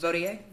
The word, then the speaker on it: Vautier: Vautier? – Thank you.